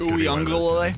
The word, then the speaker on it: Uiagalelei: I think it's Uiagalelei.